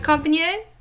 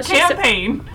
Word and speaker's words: Compiègne: It's like Compiègne. Compiègne. – Campaign. – No. – Champagne.